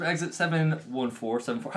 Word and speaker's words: Exit 714: We're here with the guys from Exit 714. How do you say it? Is it 714 or 714? – Exit 714. – That makes sense. I don't know if it did. Yeah, we're Exit 7-1-4.